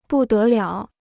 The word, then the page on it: 不得了: bù dé liǎo